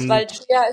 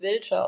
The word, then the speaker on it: Vâlcea: Ja, genau, das Vâlcea ist so die deutsche Aussprache, auf Rumänisch wird es Vâlcea ausgesprochen, genau.